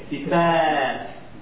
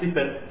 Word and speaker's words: Sifat: With a long A. Sifat. Not Sifat. Sifat.